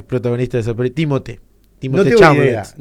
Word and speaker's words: Chalamet: Chalamet, algo así se pronuncia. – Pero sí, muy famoso. – Es muy famoso. Es como el actor de. – Chalamet. – Ahí está, Chalamet.